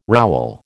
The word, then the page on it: rowel: American English